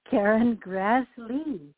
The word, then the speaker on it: Grassle: Karen Grassle. My grandfather decided that that's how it should be pronounced because we were in America, and that's how he thought it should sound. But in the town where my dad grew up, they still say Grassle.